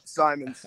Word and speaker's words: Symonds: Yep, Symonds.